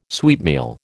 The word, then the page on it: sweetmeal: American English